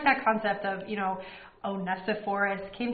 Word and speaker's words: Onesiphorus: Oh, Onesiphorus.